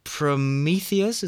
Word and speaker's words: Prometheus: There's Prometheus.